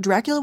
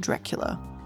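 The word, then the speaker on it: Dreach-Fhoula: It looks like it says Dreach Faula. It's spelled D-R-E-A-C-H hyphen F-H-O-U-L-A. It's apparently pronounced Dracula.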